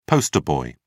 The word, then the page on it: poster boy: British English